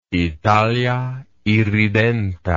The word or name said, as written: Italia irredenta